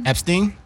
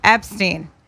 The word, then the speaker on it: Epstein: Epstein. – Epstein.